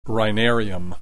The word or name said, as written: rhinarium